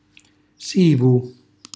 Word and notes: sivu: Calabrese

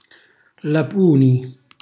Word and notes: lapuni: Calabrese

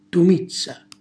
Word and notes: tumizza: Calabrese

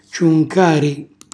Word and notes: ciuncari: calabrese

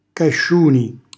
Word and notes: casciuni: calabrese

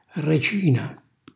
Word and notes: recina: calabrese

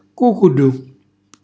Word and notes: cucullu: calabrese

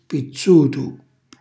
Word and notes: pizzutu: calabrese

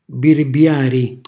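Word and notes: birbiari: Calabrian

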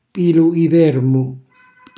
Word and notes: pilu i vermu: calabrese

